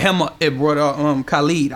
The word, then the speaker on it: Khalid: I mean, some say Khalid, some say Khalid. – It's Khalid. It's pronounced Khalid.